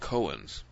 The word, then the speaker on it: Cohen: And of course, the great priests of the Hebrews were called Kohens. We spell it C-O-H-E-N. But they didn't really have any vowels then. It was more of a K-N sound, Kohen.